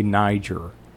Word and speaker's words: Niger: I'm not from, how do you pronounce the country? I'm going to say Niger. – That is correct.